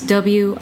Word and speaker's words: Wildrence: So that's WILDRENCE. – Yes. – .com. – Yes. Wild children. – Got it. – But pronounced Wildrence.